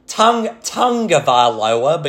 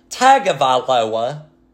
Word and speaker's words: Tagovailoa: I cannot believe that announcers still pronounce his name Tagovailoa, because there's no N in the name.